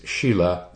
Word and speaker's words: sila: The Sanskrit word is shila. It's spelled S-I-L-A. Shila, which in Zen and probably most other forms of Buddhism.